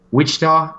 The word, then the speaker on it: Wichita: If – I think they need to win because I wasn't super impressed by the exhibition game against Wichita.